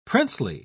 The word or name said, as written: princely